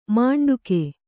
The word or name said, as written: ಮಂಡಿಗೆ